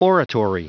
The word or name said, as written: oratory